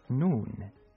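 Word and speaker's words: Nun (نون): Nun.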